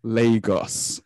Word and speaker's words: Lagos: And the way that they pronounce Lagos in the MCU is completely wrong.